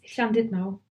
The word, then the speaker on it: Llandudno: Well, Christian says Llandudno sometimes, but it's Llandudno.